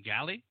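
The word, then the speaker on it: Galli: Now, Mark Galley, I believe, is the proper pronunciation of the last name.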